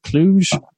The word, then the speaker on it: Cluj: I don't know how much it appears for everyone else, but recently there was the photos of thousands of people at the Cluj airport. Am I pronouncing that right? Cluj? – Yeah. – Okay, good.